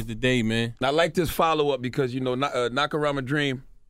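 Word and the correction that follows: Nacirema: Nasarima. – You know, I can't pronounce that. – Say American backwards.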